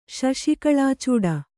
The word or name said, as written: ಶಶಿಕಳಾಚೂಡ